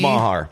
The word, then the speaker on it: Meagher: I know it's not spelled the way it's pronounced, but James Maher. – M-E-A-G-H-E-R.